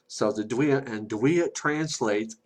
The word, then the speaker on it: Duille: There's a lot more, and part of that system is called the Duille, and it's spelled Duille. It's pronounced Duille. So L-E on the end is like a L.